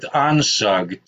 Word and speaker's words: Onsug: Onsug, technically. I mean, you really have to spell it out because this is just... – O-N-S-U-G, yeah.